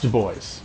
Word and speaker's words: Du Bois: It is pronounced Du Bois, yes.